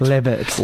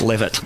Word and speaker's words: Leavitt: And I know I can never figure out how to pronounce his surname. David Leavitt or Levitt. – Levitt. – Levitt.